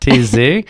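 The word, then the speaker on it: Taizé: Everyone looks at it and thinks that it's pronounced differently, but TESE is a beautiful little village.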